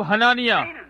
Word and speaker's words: Hananiah: तब हनानिया, मुझे लगता है आप इसका उच्चारण HANANIAH करते हैं,